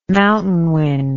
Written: mountain wind

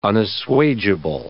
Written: unassuageable

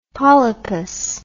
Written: polypus

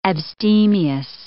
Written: abstemious

